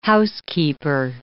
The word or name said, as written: housekeeper